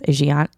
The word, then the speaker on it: Aegina: Aegina, A-E-G-I-N-A. It's like, it sounds like the Aegean Sea.